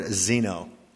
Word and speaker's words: xeno: Because the word stranger in the New Testament is the Greek word xeno. It's spelled X-E-N-O.